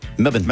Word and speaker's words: Melbourne: Melbourne. – Melbourne. Yeah, you did. – All right, Melbourne. Because I got a voice imaging tonight. Melbourne. Rams Niners in Melbourne. Week one. – Say Melbourne.